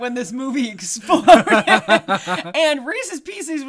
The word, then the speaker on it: Reese's Pieces: There is some contention about how to say it, but that is the correct way. – At least you don't say Reese's Pieces. – No, don't do that. That's wrong. So the thing about that, they were the second choice.